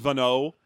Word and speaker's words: Vagno: I also read it was a silent G. His name's just Vano.